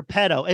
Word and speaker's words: Petto: I do think it's crazy that they call his character pedo. It's spelled P-E-T-T-O, but the way you hear it is P-E-D-O.